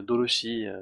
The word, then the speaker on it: Dorothy: voilà, Doroshi, si on veut faire la prononcer à la japonaise.